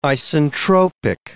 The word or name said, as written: isentropic